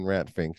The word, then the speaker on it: Pfink: First of all, if you're searching for it on Tubi, everyone, the P is silent in Rat Fink, just so you know, or else you're going to get... – It's not spelled P-H-I-N-K, it's spelled P-F-I-N-K.